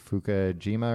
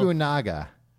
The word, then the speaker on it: Fukunaga: Fukunaga.